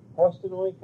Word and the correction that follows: Postojna: Cavern? – Postojna.